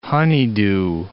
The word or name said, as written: honeydew